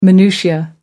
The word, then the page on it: minutiae: American English